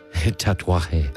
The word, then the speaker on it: tatuaje: Tatuaje.